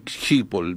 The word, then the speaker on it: Schiphol: Se escribe S-C-H-I-P-H-O-L, Schiphol, el aeropuerto de Schiphol, vamos a decirle Schiphol.